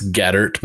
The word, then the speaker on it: Gaeddert: That's Gaeddert, but that's why I go by Bubba most of the time.